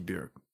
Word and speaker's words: Björk: How can you go to Iceland and not just see Björk on the street? – I assume that there's a poster of... – You know, rhymes with jerk? – It's true.